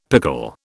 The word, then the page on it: pickle: British English